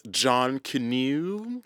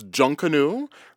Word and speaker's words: Junkanoo: In Jamaica, I want to talk about the junk canoe celebrations, or as some people might want to pronounce it, John Canoe. – John Cano. – John Cano. It's junk canoe, right? – Junk canoe.